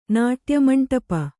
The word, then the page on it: ನಾಟ್ಯಮಂಟಪ: Kannada